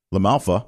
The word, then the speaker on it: LaMalfa: Representative Mo Brooks of Alabama, Andy Biggs of Arizona, Scott DeJurelos, I'm totally butchered that, Tennessee, Tom Tiffany of Wisconsin, Doug LaMafia, LaMalfa, I'm sorry,